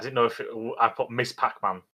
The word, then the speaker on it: Ms. Pac-Man: Yeah, it's Ms. Pac-Man. – Yes. – But pronounced Miss Pac-Man, yeah.